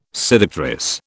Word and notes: seductress: British English

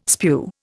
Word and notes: spew: Indian

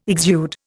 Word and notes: exude: Indian

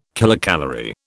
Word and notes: kilocalorie: British English

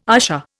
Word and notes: usher: Indian

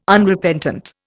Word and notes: Unrepentant: Indian

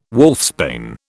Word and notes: wolfsbane: British English

